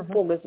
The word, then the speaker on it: Погос: Да, его Погос зовут на первое О ударение.